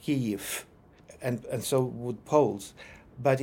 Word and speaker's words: Kyiv: So Ukrainians go in the opposite direction, and instead of devoicing it, they vocalize it. And so V becomes a short U. So Ukrainians say Kyiv. So it's a lot of information to pronounce just one two-syllable word. But if you practice it, knowing all this, Kyiv, it becomes very, very easy, Kyiv.